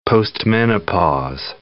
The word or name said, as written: postmenopause